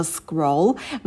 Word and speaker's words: scroll: Это слово scroll. Мы на к не добавляем придыхание, потому что к у нас идет после с, поэтому на с мы сидим подольше, а к произносим побыстрее без выдоха.